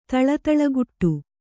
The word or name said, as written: ತಳತಳಗುಟ್ಟು